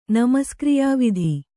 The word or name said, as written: ನಮಸ್ಕ್ರಿಯಾವಿಧಿ